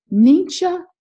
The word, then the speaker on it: Ningxia: So I want to welcome you today, and I'm going to talk today about a product called Nincha Red. That's spelled N-I-N-G-X-I-A, Red. Have you heard of it? Or have you heard of the Nincha Wolfberry?